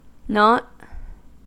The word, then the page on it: nought: American English